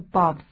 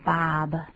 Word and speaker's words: Bob: 对，作为母音，虽然写成 O，可是发音是啊，不是 Bob，是 Bob。